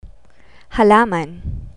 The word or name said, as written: halaman